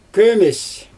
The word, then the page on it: kömes: Xyzyl